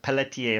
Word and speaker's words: Pelletier: His name was, well, they pronounce it Pelletier. Pelletier, a French name, really.